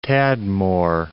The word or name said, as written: Tadmor